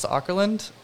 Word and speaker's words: Åkerlund: Um, the other one, I think, um, a really big influence was um, I'm gonna butcher his name, Jonas Sockerland's. Um, mm-hmm. – Jonas Åkerlund.